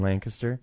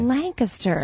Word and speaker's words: Lancaster: And enjoyed some coffee down in Lancaster. – Yeah. – So you said it right. – I did, didn't I? – Lancaster.